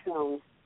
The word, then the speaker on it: Schoen: How do I say your name? I'm sorry, I didn't catch the very beginning of the program. – Oh, it's Karen Schoen.